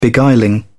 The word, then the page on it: beguiling: British English